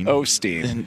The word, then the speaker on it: Osteen: Is it Ornstein? I don't know if it's Osteen. – Osteen. – Osteen. Joel Osteen. Is that how you say it? – Osteen. – It's just O? There's no L? – O-S-T-E-N.